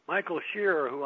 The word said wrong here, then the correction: Scheuer: I mentioned, his name is spelled S-C-H-E-U-E-R, or Shore, that would be more likely.